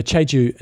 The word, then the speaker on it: Jeju: And how do you say this last one? – The Jeju International Triathlon in Korea.